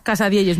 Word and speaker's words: Casadielles: Y nos trajo una cosa que se llama Casadielles, ¿no? ¿Lo digo bien? – Casadielles, muy bien dicho.